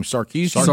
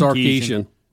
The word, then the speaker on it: Sarkisian: Sarkeesian. – Sarkeesian.